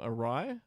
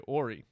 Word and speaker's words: awry: Awry, I would say Ori.